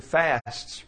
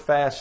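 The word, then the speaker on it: fæst: Our English word fast stems first from the Old English word fast, if I'm pronouncing that right, F-A-E-S-T.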